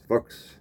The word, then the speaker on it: Fuchs: Okay, my last name is Fuchs, pronounced Fuchs in German. Yes, we know what that sounds like. But anyway, Fuchs is German. In English, that translates over to Fox.